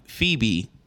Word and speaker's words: Phoebe: Phoebe.